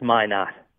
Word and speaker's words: Minot: Why not? It's Minot.